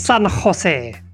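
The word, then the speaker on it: San José: It is San Jose, a Spanish vessel that was... – San Jose. It's got to come from the throat. – Thank you. – San Jose.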